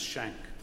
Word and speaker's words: Schenck: At the invitation of our bishop, I went to hear a man called Rob Schenck, spelled S-C-H-E-N-K, but pronounced Schenck.